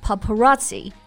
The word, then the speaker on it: paparazzi: But do you know why they're called paparazzi? – 嗯，这个我还真的不太清楚诶。但是听发音啊，就是个外来词，因为它的末尾拼写呢是 double z i，但是发的却是起这个音 paparazzi。